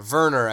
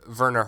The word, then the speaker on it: Werner: Yeah, Werner, I think, is how it's pronounced.